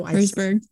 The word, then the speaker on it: Herzberg: No, Herzberg.